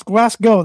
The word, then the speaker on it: Glasgow: And it's Glasgow, not Glasgow. It's Glasgow. That's how it's pronounced.